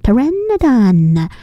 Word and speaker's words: Pteranodon: 这个字长得很特别哦，如果有小小地球人看到，有可能会觉得不是很确定怎么念，因为它是 Pteranodon，所以呢 PT 一起，就 P 没发声音就走 T，所以 Pteranodon 听看起来有一点特别的，这个字很有趣耶。